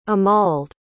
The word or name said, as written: amald